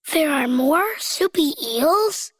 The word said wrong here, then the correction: marsupial: You almost said it right, Elliot. Let's practice one more time and say marsupial on the count of three. One, two, three. – Marsupial.